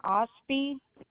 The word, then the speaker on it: Osby: Diane Osby, OST, B as in boy, Y.